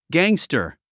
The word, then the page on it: gangster: gǽŋstər; ギャングスタァ